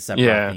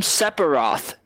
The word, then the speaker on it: Sephiroth: He called him Sephiroth and not Sephiroth. He's like, I see a PH, but hey man, that PH, the H is silent.